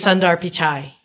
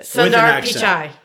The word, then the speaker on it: Sundar Pichai: His name is Sundar Pichai. – Good job. Yes. – Well done. Yes. Well done. – Say it again, just so we know how to pronounce it. – Sundar Pichai.